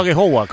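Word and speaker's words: Aghehowa: Sí, yo sé que el apellido es un poco difícil, no sé si lo he pronunciado bien, pero he dicho a Gehowa, ¿cómo se pronuncia? – Sí, bien, la verdad que así, muy bien.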